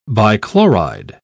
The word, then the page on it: bichloride: British English